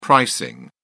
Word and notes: pricing: British English

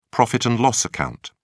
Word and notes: profit and loss account: British English

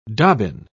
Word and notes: dobbin: British English

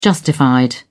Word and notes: justified: British English

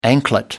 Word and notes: anklet: American English

